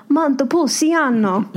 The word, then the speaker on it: Montepulciano: But there is actually Santa Claus's village of Montepulciano.